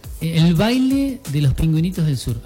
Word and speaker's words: El Baile de los Pingüinitos del Sur: El Baile de los Pingüinitos del Sur. ¿Así es el nombre? ¿Lo dije bien? – Así, ¿no? Es. Sí.